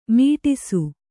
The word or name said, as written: ಮಡಿಸು